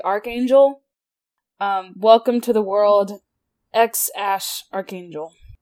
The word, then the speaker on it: X Æ A-12: The most prominent explanation that I've seen is people think the baby's name is pronounced X-Ash Archangel. X-Ash Archangel. X for the X. And then the symbol that they use for the AE is a ligature pronounced ash.